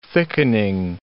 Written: thickening

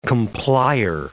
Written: complier

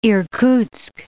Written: Irkutsk

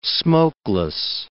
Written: smokeless

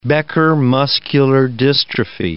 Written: Becker muscular dystrophy

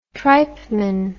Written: tripeman

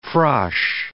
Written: frosh

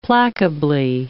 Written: placably

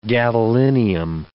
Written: gadolinium

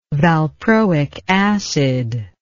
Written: valproic acid

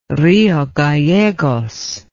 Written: Rio Gallegos

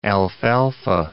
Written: alfalfa

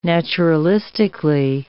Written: naturalistically

